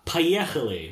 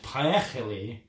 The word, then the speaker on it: Поехали: So what he said was "Поехали," which means off we go. So "Поехали."